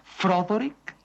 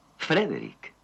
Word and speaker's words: Frederick: ¿Dice usted también Froderick? – No, Frederick. – ¿Y por qué no es Froderick Frankenstein? – Porque no, es Frederick Frankenstein.